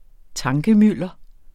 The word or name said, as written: tankemylder